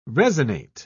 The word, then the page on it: resinate: British English